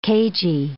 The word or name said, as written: cagey